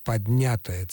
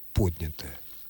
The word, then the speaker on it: поднятое: Так вот, глагол «поднять» относится к числу как раз тех глаголов, в формах которых, в том числе и в причастных формах которых, этот процесс уже произошел. Поэтому, конечно же, поднятая целина исключено категорически. Правильное ударение – поднятое.